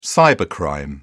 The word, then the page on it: cybercrime: British English